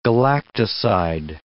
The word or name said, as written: galactoside